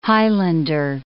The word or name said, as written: highlander